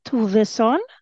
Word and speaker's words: Tovesson: So in Sweden, uh, people will say Tovesson, uh, and you hear that it ends with S S O N.